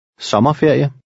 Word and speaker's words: sommerferie: And now read number 12, which again has a short O. – Sommerferie.